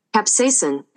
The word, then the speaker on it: capsaicin: Capsaicin? Am I saying it right? – I'm not even sure what the word you're. – Yeah, I don't know. – Capsaicine. No E. Just capsaicin.